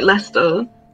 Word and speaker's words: Leicester: I think it's just like Leicester.